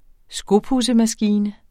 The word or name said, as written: skopudsemaskine